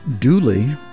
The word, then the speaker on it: Dula: And a man by the name of Tom Dula, pronounced Dooley, was convicted and hanged for that murder in 1868.